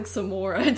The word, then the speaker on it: Samara: But I thought it, I kept calling the ring girl Samara anyway. – Yeah. – I was just like, what is Samara doing here? – It's not even, it's pronounced like Samora too, like in the actual movie.